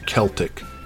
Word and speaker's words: Celtic: Um, and before I get a lot of angry emails about my pronunciation about the Celtic people, hear me when I say that both the Irish and Welsh use the letter C to indicate the sound represented by the letter K in English.